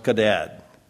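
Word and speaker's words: kadad (חדד): The word used for sharpens in verse 17 is the passage is the Hebrew word in English, chadad. It's pronounced kadad, kwadad.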